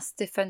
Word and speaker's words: Stephen: Euh, j'espère que je prononce bien Stephen Sondheim. J'ai un très mauvais accent anglais. Je pense euh, que c'est Stephen.